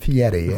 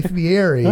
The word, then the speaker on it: Fieri: They were like, it's spelled Fieri, but you pronounce it Fieri. And if you say Fieri, especially to his face, he will be upset. So it's Guy Fieri, but yes.